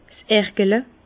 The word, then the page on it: 's Ergele: Bas Rhin; Herrlisheim